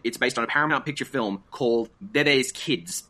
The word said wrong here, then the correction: Bebe's Kids: It's Bebe's Kids. – Bebe's Kids. I apologize.